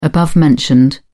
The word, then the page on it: above-mentioned: British English